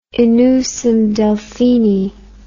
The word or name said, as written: in usum Delphini